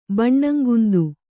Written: ಬಣ್ಣಂಗುಂದು

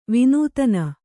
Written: ವಿನೋದಿನಿ

